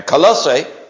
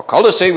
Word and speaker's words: Colossae: Now, Colossae, or Colossae, whichever way you like, I've heard it pronounced both ways, was a small town in a place known as the beautiful Lycus Valley, located about 100 miles or so east of Ephesus.